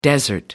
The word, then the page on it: desert: American English